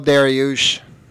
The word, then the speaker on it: Dariush: Dariush? How do you say that? – Beneil Dariush.